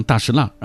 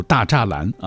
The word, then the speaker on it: 大栅栏: 尤其像大石蜡啊，如果非要按照标准读，比如大栅栏啊，也只能说它不是老北京。